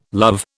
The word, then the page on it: love: British English